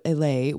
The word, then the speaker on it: Islay: Isle of Islay, I-S-L-A-Y, which the horrible pronunciation is Islay, which is where Lagavulin is.